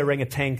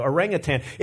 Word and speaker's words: orangutan: I want to say orangutan because it sounds better, but I know it's not spelled with a G at the end. So orangutan.